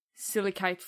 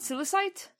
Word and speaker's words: silicate: Is it silicite? – Silicate. – Silicate. Apologies. – How'd you spell that? – S-I-L-I-C-I-T-E, which is why I pronounce it. – C-A-T-E. – A-T-E. – Silicate? – Silicate. – Oh, fuck yeah. Oh, that's why I pronounce it wrong, because I wrote it wrong.